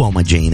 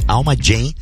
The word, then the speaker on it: Alma Djem: convidar todo mundo também pra seguir o Alma Jam, né? Alma Jam se escreve Alma DJEM. Alma Jam DJEM.